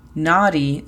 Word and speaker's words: naughty: Naughty significa travieso o traviesa. La pronunciación fonética sería N-A-R-I, naughty. Nota cómo la R es muy, muy suave.